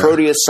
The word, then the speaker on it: Proteus: But this is, even though this is a cliffhanger and it's leading to more of the Mutant X / how do we say it? Proteus? – The saga. Proteus saga.